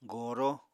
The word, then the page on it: goro: Apatani